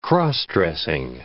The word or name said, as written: cross-dressing